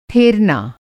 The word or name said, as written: ठेरना